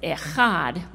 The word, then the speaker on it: Echad: Now, you might see on some of our literature the word E-H-A-D. It's not pronounced Ehad. It's pronounced Echad.